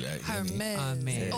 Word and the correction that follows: Hermes: Oh, sorry, Hermes. The H is silent. Hermes.